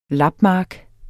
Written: lapmark

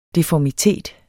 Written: deformitet

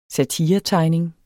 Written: satiretegning

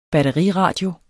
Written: batteriradio

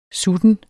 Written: sutten

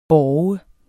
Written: borger

